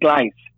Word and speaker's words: Glice: So the business is called Glice, G-L-I-C-E, like gliding and ice. – Yeah. – Glice. – Oh, okay. There you go. Like gliding and ice, Glice.